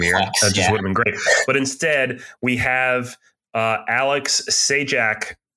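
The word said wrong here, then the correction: Čejka: I'm saying his name wrong. I can never say anyone's name right on this podcast, Alex. Say it for me because I just can't do it right. – Alex Chica.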